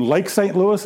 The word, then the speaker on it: Lake St. Louis: My niece lives in Lake St. Louis.